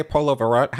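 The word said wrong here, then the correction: Puerto Vallarta: How do you say that? – Puerto Verata.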